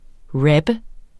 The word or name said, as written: rapper